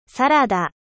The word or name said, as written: サラダ